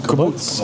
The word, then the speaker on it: kibbutz: Is that the way you pronounce it? Kibbutz? – Kibbutz. – Oh, kibbutz.